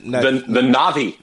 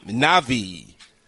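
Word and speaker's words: Na'vi: How do you say it? – The Navi. – Navi. – Yes.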